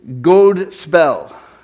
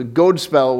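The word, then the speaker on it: godspel: in Old English got translated as God spell, G-O-D, long O, spell, S-P-E-L. So God spell was the word, and God spell simply meant good news or good story.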